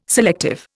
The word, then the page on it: selective: Indian